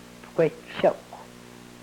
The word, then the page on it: pkwechokw: Yurok